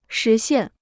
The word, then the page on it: 实现: shí xiàn